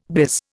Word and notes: biz: Indian

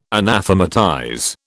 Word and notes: anathematize: British English

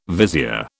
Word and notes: vizier: British English

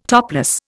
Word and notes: topless: Indian

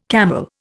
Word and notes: camel: Indian

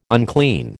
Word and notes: unclean: American English

